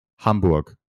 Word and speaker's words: Hamburg: How do you pronounce it? – Hamburg.